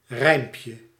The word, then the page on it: strophe: [stʁɔf]; France